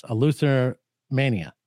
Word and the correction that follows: Eleutheromania: Oh, am I close? – You're so close. That's like tell me how do you say it? – Eleutheromania. – Eleutheromania. Okay, Eleutheromania.